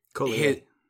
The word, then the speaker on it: Cotillard: You know Marion Cotillard is playing Cotillier. I'm pretty sure you don't pronounce that D.